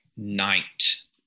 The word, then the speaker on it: knight: There's six letters in that word, K-N-I-G-H-T. We only pronounce three of them, the N, the I, and the T. – Right. – The K, but it would have been pronounced knicht at one time. Uh-huh. So you would have had a K sound at the front.